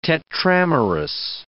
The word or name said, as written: tetramerous